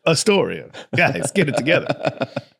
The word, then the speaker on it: Astoria: But there's Astoria, Oregon, but they pronounce it Astoria. – Astoria. – And I thought everybody was just being jerks. Like, oh, it's good to be here in Astoria. I'm like, yeah, he's really giving them the screws. Hey, we Astoria. That's kind of mean to say. They're like, no, that's how we pronounce it here.